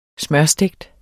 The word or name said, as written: smørstegt